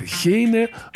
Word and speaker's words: Гейне: Кстати, надо сказать, что немцы произносят его как «хёте», вот, как и Гейне у них. Хейне, а Гегель у них Хегель.